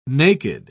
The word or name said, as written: naked